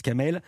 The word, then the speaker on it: Kamal: C'est écrit Kamal, mais on prononce Kamel.